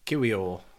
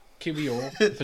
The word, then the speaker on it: Kiwior: And how do you say him? – Kiwior. – Kiwior for 20 million.